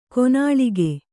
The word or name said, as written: ಕೈನಿಲುಕು